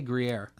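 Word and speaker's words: Gruyère: How do you say Gruyere? – Gruyere.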